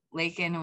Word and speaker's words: Laykin: And so Lakin was L-A, we changed it so people say it right, L-A-Y-K-I-N.